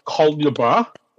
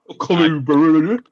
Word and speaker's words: colubrid: It's not named after colubra. Colubrid. Fucking colubrid.